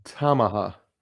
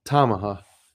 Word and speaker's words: Tamaha: Nobody in Tahoma or Tahama. Tahama? What the hell? Hang on. I saved it. I want to say it right. Tamaha. I had my emphasis on the wrong syllable. Tamaha.